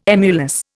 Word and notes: emulous: Indian

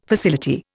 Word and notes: facility: Indian

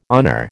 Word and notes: honour: American English

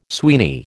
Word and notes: Sweeney: American English

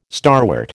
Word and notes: starwort: American English

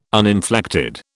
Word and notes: uninflected: British English

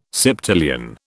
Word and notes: septillion: British English

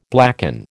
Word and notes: blacken: American English

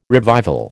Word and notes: revival: American English

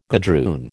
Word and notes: gadroon: American English

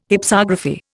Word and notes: Hypsography: Indian

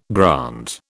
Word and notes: grant: British English

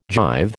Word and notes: jive: American English

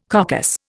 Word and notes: coccus: Indian

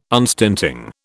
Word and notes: unstinting: British English

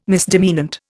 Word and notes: misdemeanant: Indian

